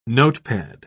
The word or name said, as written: notepad